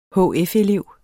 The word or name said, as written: hf-elev